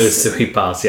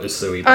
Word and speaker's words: Usui Pass: Usui Pass, yeah. God damn it, I always screw up Usui Pass. – I might be saying it wrong, I'm not sure. – No, I think it is that.